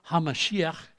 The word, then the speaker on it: HaMashiach: HaMashiach is how they pronounce it with a guttural in the back of the throat.